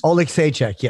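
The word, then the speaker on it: Oleksiejczuk: The next fight, can you just pronounce that for me? Because I don't want to hurt these guys' names. – Oleg Saichek, yeah.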